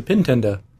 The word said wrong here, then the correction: Pitendo: The Pintendo or the Pitendo? – Something like that. Yeah, it's spelled P-I-T-E-N-D-O. So if Pitendo, you have...